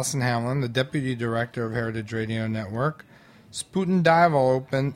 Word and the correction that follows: Spuyten Duyvil: Spuyten Dival. So the U and the Y is pronounced like an I.